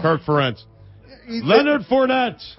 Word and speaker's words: Ferentz: Preparation has a name, and its name is Kirk Ferentz. – Oh, you butchered the name. That is great. – Ferentz. Give me the beat. Ferentz. – Ferentz.